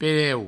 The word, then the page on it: pereu: Provençal